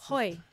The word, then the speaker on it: hoi: Surely I did not say that right. – I was going to say, is that how you say it? It's kind of hoi. Like ahoy, ahoy hoi. – But it's hoi.